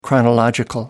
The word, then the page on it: chronological: American English